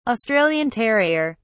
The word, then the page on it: australian terrier: British English